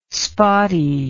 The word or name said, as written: spotty